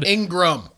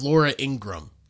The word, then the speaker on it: Ingraham: Conservative expert for Fox News host Laura Ingraham said... – Ingram. – No, it's spelled Ingraham. – I know how it's spelled. It's Laura Ingram.